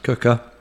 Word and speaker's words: Quooker: Anyway, this podcast is sponsored by Cooker, spelt Quooker, pronounced Cooker.